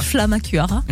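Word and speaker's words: Flammekueche: Pour moi et ma famille, ce sera un repas traditionnel qui va nous rassembler, la tarte flambée, flamme-cure, comme diraient les Alsaciens. Je l'ai bien prononcé ou pas ? – Flamme-cure. – Flamme-cure, voilà.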